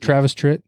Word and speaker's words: Travis Tritt: Did I say that right? Is it Travis Tritt? – Oh yeah, mixed with like a 40-year-old lesbian mom with a Fu Manchu lightning mustache.